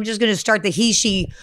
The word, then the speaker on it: Heche: It's Heche, but I always hated that because it sounded like hate.